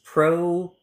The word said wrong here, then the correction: propranolol: So I would pronounce it propranolol.